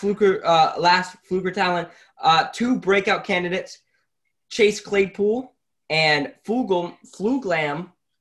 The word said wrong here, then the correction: Fulgham: can't even say his name, for you to say. – Of the Eagles. Eagles receiver Travis Fulgham, that's his name, had 13 targets, 10 receptions, 152 yards, 1 reception, and only his second game as an Eagle.